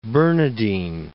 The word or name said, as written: Bernardine